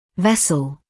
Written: vessel

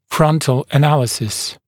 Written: frontal analysis

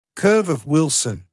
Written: curve of Wilson

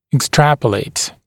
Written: extrapolate

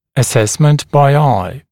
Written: assessment by eye

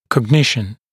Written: cognition

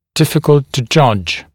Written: difficult to judge

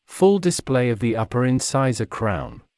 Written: full display of the upper incisor crown

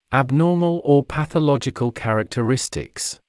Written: abnormal or pathological characteristics

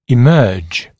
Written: emerge

